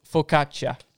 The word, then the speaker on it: focaccia: So it's focaccia. – Yeah, absolutely. A double C in the I is a sound.